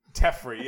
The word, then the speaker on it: Teferi: Teferi, yeah, I'll never. Yeah, it's not Jeffrey, like with a T.